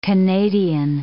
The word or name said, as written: Canadian